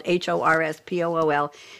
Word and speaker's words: Horspool: And our third guest, I didn't say last because you're all equally wonderful, is Jennifer Horspool. She told me to pronounce her name just like it's spelled, Horspool.